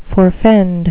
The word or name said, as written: forfend